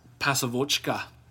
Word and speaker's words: Pasovochka: Dynamo played a style known as Pasovochka. – Okay. Is that Russian? – Yeah. – Kudos on the pronunciation. – Thank you. Pasovochka.